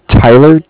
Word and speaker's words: Tyler: Yeah, my name is Tyler, T-Y-L-E-R, mm-hmm. – And I'm calling with Cardiff. It's spelled C-A-R-D-I-F-F, as in mm-hmm.